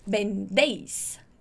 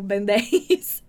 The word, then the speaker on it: vendéis: Você não vai falar vendéis, tipo vendéis. Você vai falar vendéis, vendéis, com esse som de B mesmo.